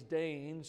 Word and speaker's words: Dane: Now, my name is Dane, so what rhymes with Dane? Pain.